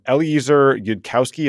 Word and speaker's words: Eliezer Yudkowsky: Then there are AI researchers like a guy named Eliezer Yudkowsky. Am I saying that correctly? – That's right. Yeah.